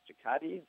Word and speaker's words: Cicotte: Eddie Cicotte, that's how you pronounce his last name.